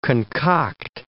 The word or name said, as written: concoct